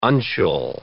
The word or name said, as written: uncial